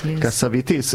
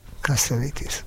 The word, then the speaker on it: Cassavetes: Renate azt mondta, hogy kell Cassavetes nevét kiejteni. Cassavetes. Igen, én mindig Cassavetesnek mondtam. – Én is Cassavetesnek mondtam. – Igen, egy amerikai barátnőmtől hallottam. – Ja, kiejtette, és így kell.